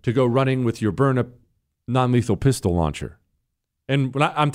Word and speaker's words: Byrna: Did you know that Byrna, they sell, it's spelled B-Y-R-N-A.